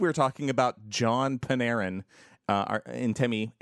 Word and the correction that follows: Artemi: Panarin, who... – Artemi. – Thank you. – I think I'm pronouncing it right. I don't know. Sometimes I second guess my pronunciation of things. So I think it's Artemi.